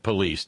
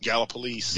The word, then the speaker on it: Gallipolis: Oh, by the way, you mispronounced Gallipolis tonight. – No, I did. I said police, didn't I? – Yeah, you did. Gallipolis. – Gallipolis rhymes with piss. – Yeah, it's just Gallipolis. Two syllables.